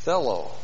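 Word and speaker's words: Thello (θέλω): Aha. Thello. T-H-E-L long O. Thello.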